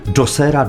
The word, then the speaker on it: Djoser: Nie Bóg, to był chyba człowiek, bo wiemy, że on żył za czasów faraona Djosera Djesera, bo różnie się to wymawia. Ja wolę tę wersję Djoser, więc pozwolę sobie trzymać się tego brzmienia.